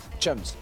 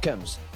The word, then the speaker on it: chums: Bonsoir Antoine, and bonsoir my British cum. – Chums. – What? – It's chums, not cums. You must pronounce the H, Jean-Paul.